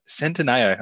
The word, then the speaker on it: Centineo: if we've gone to the effort to get it right, Centineo.